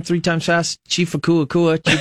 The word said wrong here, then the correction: Atakua Kua: Akua Kua, Chief Akua Kua? – Chief Atakua Kua. – Okay, Chief Atakua Kua, Atakua Kua, Atakua Kua.